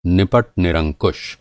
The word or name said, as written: निपट